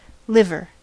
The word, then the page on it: liver: American English